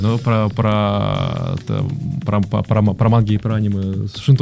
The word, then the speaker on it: аниме: Кстати, та же самая Александра Артемьева, которая спросила тебя про гик и исправила тебя. Аниме. Ударение написала она, здравствуйте. – Аниме. – Да, на самом деле, да, аниме, так что смиритесь, мы тоже когда-то смирились.